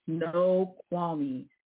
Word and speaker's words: Snoqualmie: So if you ever see the word Spokane – S-P-O-K-N-E, it is not Spokane, it is Spokane. You might see something called Snoqualmie. It is Snoqualmie. It's spelled S-N-O-Q-U-A-L-M-I-E.